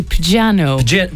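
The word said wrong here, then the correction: Pjanoo: Piano. – Is it a silent J? – Piano.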